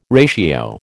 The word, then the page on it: ratio: American English